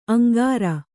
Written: ಅಂಗರ

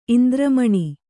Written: ಇಂದ್ರಮಣಿ

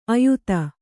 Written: ಆಯತಿ

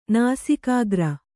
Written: ನಾಸಿಕಾಗ್ರ

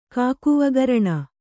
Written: ಕಾಕುವಗರಣ

